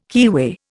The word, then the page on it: keyway: Indian